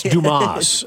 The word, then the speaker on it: Dumas: It's Dumas. It's pronounced. It's French. It's Dumas. Yeah.